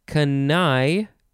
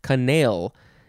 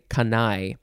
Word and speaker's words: canaille: Next we have canai, or it could be pronounced canale, C-A-N-A-I-L-L-E. We'll say canai.